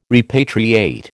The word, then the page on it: repatriate: American English